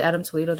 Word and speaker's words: Toledo: And that video that I saw, is it Adam Toledo? Did I say it right? – Yeah. – Okay, Adam Toledo.